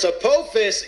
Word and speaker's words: Apophis: APEP also goes by the name of Apophis, and of course, Apophis is the serpent. – It's Apophis, and I know I'm being a grammar Nazi. I just want you to understand that that's the actual proper pronunciation, and that's I've seen Apophis in a lot of different sci-fi shows pushing Apophis.